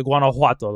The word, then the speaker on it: Guanajuato: It's not actually pronounced with a J. It's it's like a like a H, more like a H sound. – Yeah, it's like an H U sound like a hua, like a Guanajuato, like that.